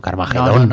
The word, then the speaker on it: Carmageddon: No, con acento en la O, Carmageddon.